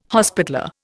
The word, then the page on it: hospitaller: Indian